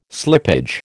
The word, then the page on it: slippage: American English